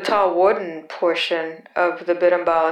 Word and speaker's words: Berimbau: The word Berimbau is spelled B-E-R-I-M-B-A-U. It is pronounced in three syllables. Berimbau. Berimbau. The tall single string instrument is called the Berimbau.